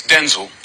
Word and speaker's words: Denzel: Actually, it's Denzel. – Oh, no, is it really? – Yeah, he was Denzel.